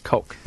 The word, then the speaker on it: Koch: K-O-C-H. It's pronounced Coke. Like Coke.